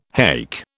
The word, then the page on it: haik: British English